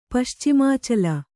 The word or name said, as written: ಪಶ್ಚಿಮಾಚಲ